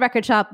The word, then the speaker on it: RCRD SHP: It's called Record Shop, but it's spelled without any of the vowels, R-C-R-D-S-H-P.